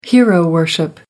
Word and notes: hero-worship: American English

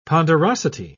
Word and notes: ponderosity: British English

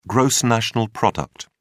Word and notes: gross national product: British English

